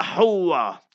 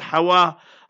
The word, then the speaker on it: Hawwa: And when he, Nabi Adam alayhi salam, saw that his honorable wife, Eve, Sayyida Hawa, H-A-W-W-A, not Hawa, Hawa, learn to pronounce properly, Jumad al-Ula, not Jamad al-Ula.